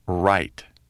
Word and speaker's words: write: The letter W is silent in the word write. W-R-I-T-E. The letter G is silent in the word gnome, G-N-O-M-E.